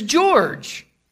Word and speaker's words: Georg: If you listen closely to the sound of music, Mr. Von Trapp's name is George. And he's called Georg because that's how George is pronounced in German.